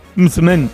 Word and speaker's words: msemen: Es una palabra complicada, decía, es M-S-E-M-E-N. Mitzmen, Mitzmen, ¿vale? Y se pronuncia como lo ha hecho Hakima. Pero nunca, nunca se pronuncia como lo vamos a escuchar en boca de este TikToker, porque puede ocasionar confusiones.